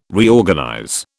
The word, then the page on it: Reorganize: British English